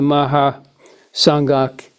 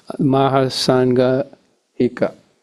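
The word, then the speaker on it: Mahāsāṃghika: And one example is the Mahasanghika. Excuse me, I didn't actually pronounce it right. The Sanskrit word that gets tangled in my head. Mahasanghika.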